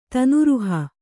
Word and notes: ತನೂರುಹ: Kannada

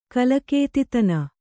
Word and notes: ಕಲಕೇತಿತನ: kalakētitana; Kannada

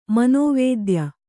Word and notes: ಮನೋವೇದ್ಯ: kannada

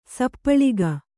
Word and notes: ಸಪ್ಪಳಿಗ: kannada

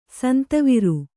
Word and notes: ಸಿಂಧುವಾರ: sindhu vāra

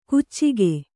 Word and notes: ಕುಚ್ಚಿಗೆ: kuccige

